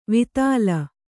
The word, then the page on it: ವಿತಲ: kannada